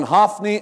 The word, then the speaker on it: Hophni: The prophet says, this shall be a sign to you that will come upon your two sons, on Hophni and Phinehas. It's the proper Hebrew pronunciations.